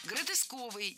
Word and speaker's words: гротесковый: Гротесковый, неверно. Правильный вариант – гротесковый. Кстати, если мы хотим образовать от существительного «гротеск» прилагательное, то предпочтительным вариантом будет слово «гротескный» с однозначным ударением на втором слоге. Вариант «гротесковый» тоже допустим, но ударение на втором слоге сохраняется. Гротеск, гротескный, гротесковый.